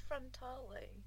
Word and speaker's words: Frontale: Isn't it pronounced frontale? – Sorry? – Isn't it pronounced frontale? – I guess it must be. – I think it is.